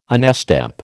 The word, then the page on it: onestep: American English